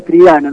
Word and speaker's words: Triana: Que originalmente se escribe Troideana, pero se pronuncia Triana.